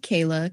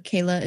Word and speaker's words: Kayla: So that is just Rebuilding with Kayla. Kayla is spelled K-A-Y-L-A.